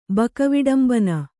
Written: ಬಕವಿಡಂಬನ

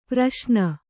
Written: ಪ್ರಶ್ನೆ

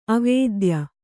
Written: ಅವಿದ್ಯೆ

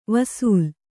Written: ವಸಲೆ

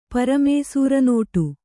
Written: ಪ್ರಾಮಿಸರಿನೋಟು

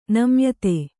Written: ನಮ್ಯತೆ

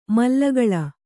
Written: ಮಲ್ಲಗಳ

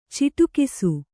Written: ಚಿಟುಕಿಸು

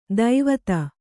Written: ದೈವತ

